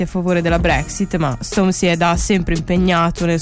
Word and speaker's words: Stormzy: Sto parlando di Stormzy, giuro che si dice così, ho cercato la pronuncia su Google 3 secondi fa.